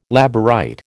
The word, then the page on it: Labourite: American English